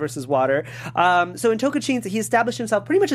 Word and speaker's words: Tocantins: Tocantins.